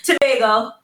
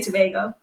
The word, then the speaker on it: Tobago: Don't feel bad, because a lot of people sometimes do pronounce it. I guess depending on where you come from, you pronounce it differently. So, yeah, but it's Tobago. In our island, we say Tobago.